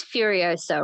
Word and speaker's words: Furiosa: She's also set to play It is Furiosa, right? That's how you pronounce it.